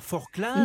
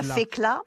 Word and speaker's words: Forclaz: La Forclaz? – Non, on dit la Fécla. – Ah, voilà, la Fécla. – Le col de la Forclaz, la Fécla. – D'accord. Oui, ça, on ne prononce pas les Z.